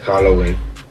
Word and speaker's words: Halloween: Feliz Juan Willy, ¿cómo se dice? – Feliz Halloween. – Feliz Halloween, grupo, soy Vanessa. – No sé quién eres, pero me parece que no se dice así, se dice Halloween.